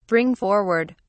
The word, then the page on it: bring forward: British English